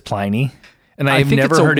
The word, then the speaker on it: Pliny: There is a Quora article, which we will link into the show notes, with a video of the head brewer at Russian River saying Pliny the Elder versus Pliny the Elder.